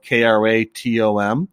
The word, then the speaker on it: kratom: It's spelled K R A T O M sometimes pronounced kratom, and it's a it's a leaf from a tree that grows in Southeast Asia, Asia, and areas like uh, Vietnam and Indonesia.